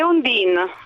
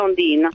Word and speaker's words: Ondine: Beh, è Ondine, perché è un nome che è stato voluto da mia madre, però sì, comunque si pronuncia Ondine, perché in francese si dice Ondine.